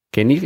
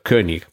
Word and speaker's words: König: König, escrito con una O con diéresis, que en alemán, si no estoy equivocado, se pronuncia como una especie de E con la boca en forma de O o algo así.